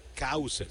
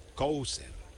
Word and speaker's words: Cowser: Se escribe C-O-W, como la vaca, cow, pero el nombre es, o el apellido es Cowser, termina con S-E-R, Cowser.